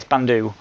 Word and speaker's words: Bandu: I've got I got the expo another similar game called Bantu, I think. Bantu or Bandu? One or the other. Bandu, I think. I always get the name wrong. Look at that. Yes, Bandu, which came out, which is kind of similar.